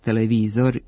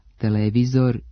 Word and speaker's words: televizor: Imenice televizor i radijator, koji imaju dugosilazne akcente na trećem slogu, pravilno se akcentuju kao oblici sa kratkouzlaznim akcentima na drugom slogu i dužinom na mestu starog akcenta, televizor i radijator.